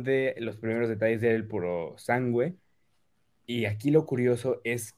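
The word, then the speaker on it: Purosangue: En primer lugar, nos hablaron de el Ferrari, que ya sé cómo se pronuncia, llevamos diciéndolo mal todo el tiempo, no es puro sangue, es puro sangüe.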